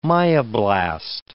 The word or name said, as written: myoblast